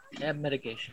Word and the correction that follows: abnegation: How do you pronounce it? – Abnegation.